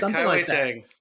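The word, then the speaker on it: Kai-Wei Teng: I was like, is it Kaiwen Tang? – That's right, Kaiwei Tang.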